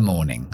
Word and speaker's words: morning: Y ya termino the morning. Recuerda the pegado a la siguiente palabra. The morning. The morning. Estadounidense pronunciaría la R después de la O. Morning. Morning. The morning. Yo digo morning.